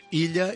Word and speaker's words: Ilha: Esto de la Ilha, a mí, como no catalán, sabes que la doble L cuesta. – Pronúncialo tú. – Ilha Imaginada. – Ilha. Cuesta un montón, por ahí nos pillan a los no catalanes, entre la doble L y la X, ¿no?